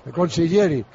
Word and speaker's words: Consiglieri: Porque el Torsiglieri este, como se llame, le ha metido ahí lo que no son los secretos. – Consiglieri. – ¿Cómo se llama? Consiglieri.